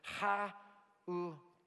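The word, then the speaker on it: hanukkiah: Candelabra here, Hanukkah, maybe is how you say this. Ha-u-kiah would be closer in the Hebrew, okay?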